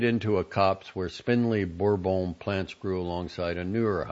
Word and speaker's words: Bourbon: Battelle's farms are dominated by a temperamental but fertile cultivar called Bourbon, pronounced Bourbon, a member of the Arabica species named for the Indian Ocean island of Réunion, formerly known as Bourbon, where it thrived.